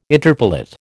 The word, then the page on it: interpellate: American English